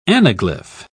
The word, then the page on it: anaglyph: British English